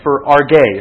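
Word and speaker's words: for arge (ὀργή): He says this: depending on the context, for arge is how this word's pronounced.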